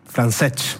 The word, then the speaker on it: Francesch: Francesch, para mí es Francesch. – Sí, ahora vamos a preguntar, así. – Francesch, sin la S, no pronuncian la S.